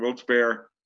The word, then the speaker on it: Wilkes-Barre: Wilkes-Barre Rail Riders, where we got rained out.